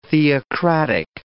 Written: theocratic

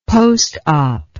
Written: postop

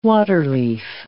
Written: waterleaf

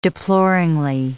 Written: deploringly

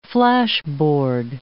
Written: flashboard

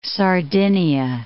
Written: Sardinia

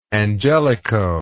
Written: angelican

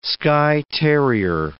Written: Skye terrier

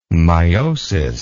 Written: myosis

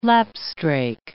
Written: lapstrake

